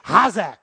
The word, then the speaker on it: chazak: You don't pronounce the C and the H. You got to come from the back of your throat real deep. Hazak.